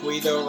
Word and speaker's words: Guido: Guido rhymes with good.